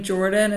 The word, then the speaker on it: Jordin: And my name Jordin is spelled with an I-N, so it's J-O-R-D-I-N.